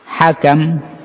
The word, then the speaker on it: hakam: Kemudian selanjutnya kata Syekh Rahimahullahu Ta'ala menyebutkan walafdul hakam. Walafdul hakam bacanya disebutkan bifathatain, dengan membaca dua fathah, yaitu ha sama kaf.